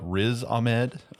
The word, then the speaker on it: Riz Ahmed: Is it Riz Ahmed? – Yeah, that's how I've heard it pronounced. – Okay, Riz Ahmed.